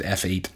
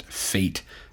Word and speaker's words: F8: And I'm pretty sure you pronounce it F8. I refuse to pronounce it fate, because Facebook fate would terrify me.